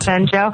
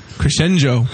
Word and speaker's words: Crescenzo: Crescenjo. Yeah. He said, pronounce it like crescendo, but add a G-O, and it's just too hard. – Crescenzo.